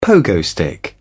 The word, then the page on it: pogo stick: British English